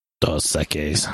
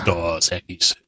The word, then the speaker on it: Dos Equis: Dos Equis. – Dos Equis. Yep. Yeah, it's the only time people say that correctly as a Greek guy.